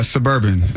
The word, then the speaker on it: SBRBN: What? SBRBN? Mm-hmm. Suburban is how you pronounce it.